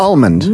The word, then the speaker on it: almond: Do pronounce the L. – No, almond. – It's almond.